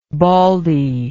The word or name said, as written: baldie